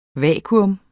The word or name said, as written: vakuum